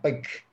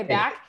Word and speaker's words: Bakke: Am I saying it right? – Yeah, Bakke. Yeah. – Okay, Bakke.